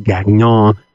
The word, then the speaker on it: Gagnon: I'm sure it's pronounced Gagnon, you know, French and all that, from Canada.